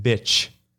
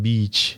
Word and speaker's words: beach: So I was reading this script and there was like this sentence that says, the people were going to the beach, but I pronounce it bitch. – Oh. – Instead of saying beach.